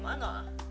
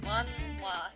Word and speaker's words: Manhwa: Well, then I would be calling it mana. – Mana? – Mana is, mana? – Manwa. – It's manwa.